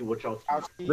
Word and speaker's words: Wachowski: And how do you say that? Is it Lana and Lily Wachowski? – Lana and Lily Wachowski. – There you go.